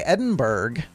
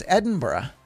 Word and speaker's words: Edinburgh: Now, some people might say Edinburgh because that's the way it's spelled, but no, it's Edinburgh.